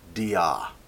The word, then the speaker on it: dia: There's a simple but great word for this in the Greek, dia. Spelled D-I-A and pronounced D-A.